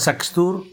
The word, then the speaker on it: Xasthur: En este caso se llaman Saxtur, que se escribe Xasthur.